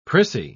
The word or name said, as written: prissy